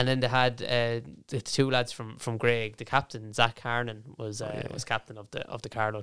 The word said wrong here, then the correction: Hernan: Hernan, I think. – Hernan is how they pronounce it.